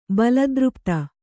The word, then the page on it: ಬಲದೃಪ್ತ: Kannada